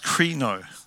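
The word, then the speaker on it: krino (κρίνω): And it's pronounced krino. This is how it's pronounced in Greek.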